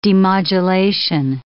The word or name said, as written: demodulation